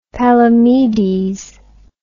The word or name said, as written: Palamedes